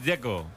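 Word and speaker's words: Džeko: Bruno dice que es Dzeko. – Como tirando a J, Dzeko o algo así.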